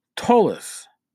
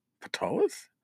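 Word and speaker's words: Ptolus: Tolis, with a silent P. I always thought it was Patolis?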